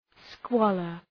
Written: squalor

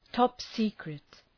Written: top secret

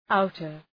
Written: outer

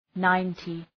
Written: ninety

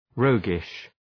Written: roguish

